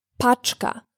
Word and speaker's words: paczka: Package in Polish is paczka.